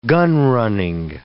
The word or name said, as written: gunrunning